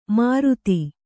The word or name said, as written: ಮೂರುತಿ